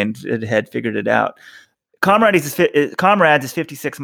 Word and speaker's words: Comrades: Comrades. You guys, there is a word in the English language called comrade. I don't understand how you keep pronouncing this wrong.